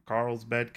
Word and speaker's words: Carlsbad: There was a helicopter crash in Carlsbad, California.